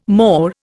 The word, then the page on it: mor: Indian